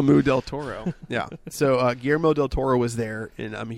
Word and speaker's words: Guillermo: Not Gorilla Moo. Not Gorilla. Guillermo del Toro. I can say his name perfectly fine.